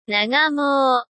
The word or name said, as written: nagamou